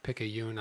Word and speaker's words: Picayune: First of all, the pronunciation of the word is Picayune, not Picayune. – It's okay. – Picayune. I'm looking it up here. So I mispronounced that.